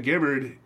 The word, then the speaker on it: Gibbard: It's just not Jim. – It is definitely not Gibbard.